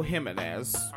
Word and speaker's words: Jimenez: Sometimes I hear folks pronounce that name Jimenez. I know Jimenez as the pronunciation.